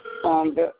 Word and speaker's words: Fonda: Fonda Watson. – What? Who is it now? – Oh, I'm sorry. Fonda, it's in Frank. O-N-D-A.